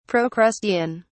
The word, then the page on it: procrustean: British English